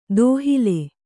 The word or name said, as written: ದೋಹಿಲ್